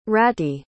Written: rati

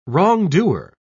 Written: wrongdoer

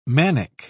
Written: manic

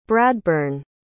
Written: bradburn

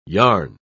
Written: yarn

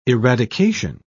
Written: eradication

